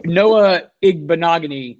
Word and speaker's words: Noah Igbinoghene: So I look at last year, Noah Igbinoghene, I think I pronounced that right, right? – You got it exactly right.